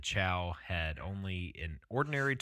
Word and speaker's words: Chau: Uh, in November of 2018, John Allen Chau Chau Chau Chau have I been saying that right this whole entire time? Sure. Sure. Chau. Yeah, John Chau.